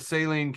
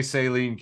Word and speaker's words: Saline: Apparently, we had a resident of Saline County, and she told me that, no, this is incorrect. They say Saline County. And I was so, because I posted a pronunciation from an Illinois pronunciation guide, and it said Saline.